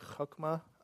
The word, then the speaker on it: chokmah: It sounds like chokmah, but it's like chokmah. It has the little ch in it.